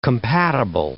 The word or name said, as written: compatible